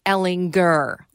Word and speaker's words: Ehlinger: Sam Ehlinger. – Yes. – Hard G.